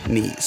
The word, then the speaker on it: Nies: And just just right off the bat, I'd just like to mention last time um, I mispronounced his name Nyes, and I, I feel kind of silly about it because I've known him a really long time, but here I am mispronouncing his last name. So I felt a little silly, so it's actually pronounced knees.